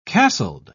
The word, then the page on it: castled: British English